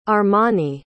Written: armani

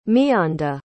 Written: miyanda

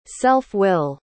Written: self-will

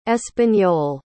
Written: espanol

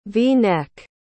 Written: v-neck

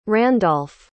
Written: Randolph

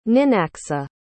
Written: ninacska

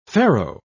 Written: faro

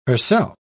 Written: herself

